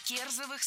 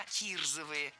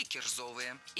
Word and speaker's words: кирзовые: Это неверно. Что удивительно, в этом слове двойное ударение. Ну и, казалось бы, ошибиться в произношении невозможно. Однако здесь есть еще одна сложность. В нашем прилагательном в первом слоге должна быть гласная «и». И сапоги не кирзовые, а кирзовые или кирзовые.